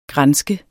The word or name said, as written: granske